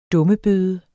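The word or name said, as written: DUMMEBØDE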